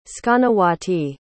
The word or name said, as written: skanawati